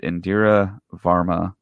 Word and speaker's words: Indira Varma: So there's a lot of pictures being taken on the set of Obi-Wan Kenobi, and of course we know that Indira Varma, I believe that's the way you pronounce her name.